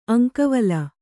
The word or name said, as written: ಅಂಕವಲ